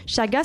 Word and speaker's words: chaga: Chaga, ça se prononce C-H-A-G-A.